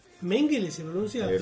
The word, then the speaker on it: Mengele: Mengele. No, se pronuncia Mengele.